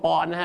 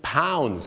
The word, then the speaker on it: pounds: ออกเสียงว่าปอนด์นะฮะต้องเป็น pounds ครับผ